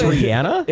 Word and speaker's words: Rihanna: Were you a part of, like, where did you fall when everybody in radio was like, it's Rihanna and not Rihanna? – I always knew it was Rihanna. – It's Rihanna. – Well, then Rihanna. – I said that wrong, but that's, okay. I feel like, a little more common. – What? – Wait, it's Rihanna? – It is Rihanna, yeah.